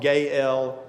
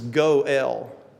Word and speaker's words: goel: I think in the past maybe I've called it gael, G A A El, but I thought you know an easy to understand pronunciation, which to me just makes even more sense. So it's my pronunciation is goel.